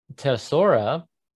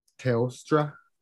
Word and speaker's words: Teostra: Teosora. – Teostra.